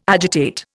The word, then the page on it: agitate: Indian